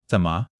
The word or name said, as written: zen3 me5